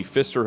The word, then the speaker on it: Pfister: Mookie Betts refuses to stay at the Pfister Hotel. I hope I'm saying that right. P-F-I-S-T-E-R. – That's it. You got it. – T-E-R. – P is silent.